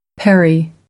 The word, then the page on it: parry: American English